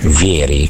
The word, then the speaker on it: Vieri: Senti, poi c'è Dido che ci scrive, scusate, ma è possibile sapere l'esatta pronuncia di Vieri? Ecco, perché insomma ne abbiamo parlato poco di questo tema, per cui si dice Vieri.